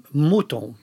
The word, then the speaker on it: Mouton: Monton Rothschild. Jag vet inte hur man uttalar det. – Inte Monton, utan Mouton. – Mouton Rothschild.